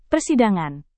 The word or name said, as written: persidangan